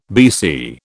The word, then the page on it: BC: British English